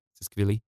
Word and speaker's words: Tsikvili: But if you just type in Tsiskvili, which there's no way you'll figure out how to spell that without me spelling it for you. So uh, T S I K V I L I, Tsiskvili.